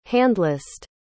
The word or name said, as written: handlist